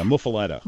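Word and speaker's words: muffuletta: Well, and I should ask you how to say it. – Yeah, muffalata. – Muffalata. – Muffalata. People say it two different ways.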